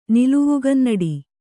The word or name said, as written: ನಿಲವುಗನ್ನಡಿ